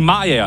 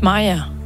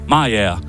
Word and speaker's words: Mariager: I 2022, der påstod en dansker i Mariager, at hun havde fundet en trøffel, som kunne slå alle rekorder. – Det udtales Mariager. – Mariager.